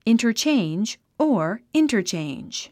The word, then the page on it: interchange: ìntərtʃéindʒ; インタァチェンジ